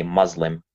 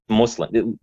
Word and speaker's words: Muslim: They don't say Muslim. That's a completely American, a Western way of saying it. But if you go say that in Algeria, people will think you're nuts. So it's a pronunciation issue. Okay. – For the rest of the show, the word is now Muslim. Muslim. – It's Muslim. Just Muslim. Say the Muslim. the way you'd say put or something.